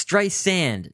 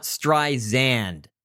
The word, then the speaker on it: Streisand: It's Streisand, not Streisand.